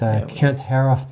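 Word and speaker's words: Haruf: So Marilynne Robinson is one of my favorites, and Kent Haroff before he passed. That's how you pronounce his name.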